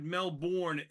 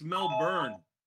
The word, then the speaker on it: Melbourne: And my Taiwanese sources say you butchered Melbourne. It's Melbourne. – What'd I say? – You said Melbourne. – Melbourne? – It's more like Melbourne.